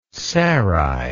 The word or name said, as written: sarai